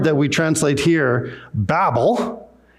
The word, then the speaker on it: Babel: And today we're going to look at the story of Babel. And some people pronounce it Babel, however you want to pronounce it.